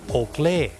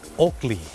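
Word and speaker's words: Oakley: โอเกลถูกไหมครับคนไทยออกเสียงว่าโอเกลแต่เจ้าของภาษาไม่ได้ออกเสียงว่าโอเกลครับเขาออกเสียงว่าโอกลีโอกลี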